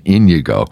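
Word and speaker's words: Íñigo: And you know, he was baptized with the name Inigo, which in Latin is Ignatius, or I should say Inigo. I have to pronounce it with the right accent, Inigo.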